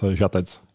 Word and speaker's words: Žatec: Did I say it correctly? – Yeah, that's great. I mean, great pronunciation. It's Žatec.